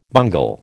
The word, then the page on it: bungle: American English